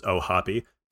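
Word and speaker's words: O'Hoppe: And I actually just learned today that it is pronounced Ohapi because of Eric Cross's panel on prospects today.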